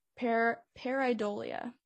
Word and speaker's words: pareidolia: But I think another reason that could explain what's in the photos is just, you know, they call it this thing called pareidolia is how you pronounce it,